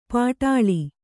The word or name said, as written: ಪಾಟಾಳಿ